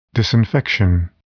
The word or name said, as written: disinfection